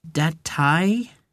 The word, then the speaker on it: Datei: How do you pronounce it? Datei? – Correct.